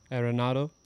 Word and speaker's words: Arenado: Arenado.